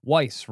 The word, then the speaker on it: Weis: Weis rhymes with nice.